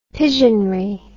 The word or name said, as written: pigeonry